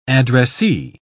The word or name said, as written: addressee